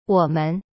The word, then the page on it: 我们: wo-men